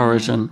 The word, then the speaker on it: Origen: Am I saying that right? Origen. – Origen, I think. – Origen, yeah.